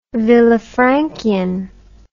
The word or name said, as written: villafranchian